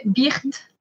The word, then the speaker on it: Berthe: Berthe, is that how you pronounce her name? – In French, we say Berthe.